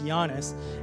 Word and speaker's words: Giannis: It looks like it's Giannis, but it's pronounced Giannis.